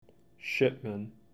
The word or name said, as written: Shipman